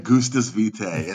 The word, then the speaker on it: Gustus Vitae: Gustus Vitae. And it's crazy. I don't even know how I pronounced it right. I found them randomly, and I remember, like, after posting about it for like 6 months online, the owner's like, oh, you say the name perfect.